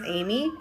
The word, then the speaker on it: Aemee: My name is Amy, and y'all can follow me on my Instagram. It's Amy, A-E-M-E-E.